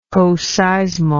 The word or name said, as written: coseismal